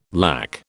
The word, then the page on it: LAC: British English